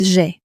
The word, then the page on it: j'ai: French